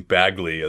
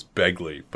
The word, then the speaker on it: Bagley: I don't know why you were pronouncing Bagley as Begley, but it was I was enjoying it.